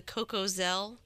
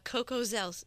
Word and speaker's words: Cocozelle: Cocozelle, C-O-C-O-Z-E-L-L-E. Maybe it's Cocozelle. – Okay. – Cocozelle. I don't know.